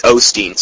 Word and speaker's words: Osteen: It's Osteen, sorry.